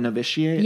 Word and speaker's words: Novitiate: You were in a movie called Novitiate.